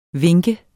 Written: vink

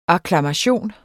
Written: akklamation

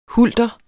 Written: hulter

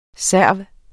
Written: serv

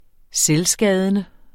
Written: selvskadende

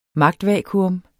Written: magtvakuum

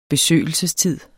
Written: besøgelsestid

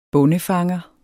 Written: bondefanger

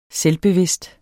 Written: selvbevidst

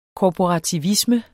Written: korporativisme

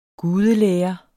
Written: gudelære

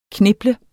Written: kneble

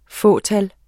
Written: fåtal

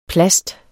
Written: PLASTET

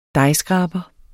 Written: dejskraber

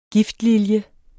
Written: giftlilje

